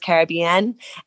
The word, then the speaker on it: Caribbean: Some islands call it Caribbean, but most islands the pronunciation is Caribbean.